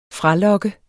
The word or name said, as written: fralokke